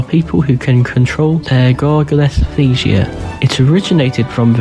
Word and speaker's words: gargalesthesia: This week's word is gargalesthesia. It is spelt G-A-R-G-A-L-E-S-T-H-E-S-I-A.